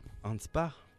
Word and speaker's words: Hanspach: Hanspach. Ça se prononce comme ça.